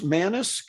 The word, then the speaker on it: Mannes: Then she went on to study at, how is it pronounced, Manus College? – Right.